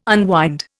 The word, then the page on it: unwind: Indian